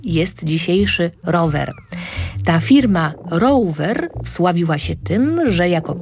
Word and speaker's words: Rover: Takie wszystkie pojazdy nazywane były velocypedami i właśnie velocypedy produkowała około 150 lat temu firma angielska, której nazwę pisze się tak jak dzisiaj, czyli R-O-V-E-R, a czyta się po angielsku Rover.